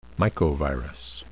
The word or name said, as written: mycovirus